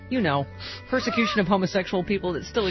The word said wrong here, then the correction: persecution: I think you mean persecution. – Yeah. I believe you put the emphasis on the wrong syllable again.